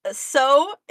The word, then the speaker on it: sew: Because sew is actually a word that I used to have, like, a very hard time saying. Because it looks like... It doesn't look like it would be pronounced like O.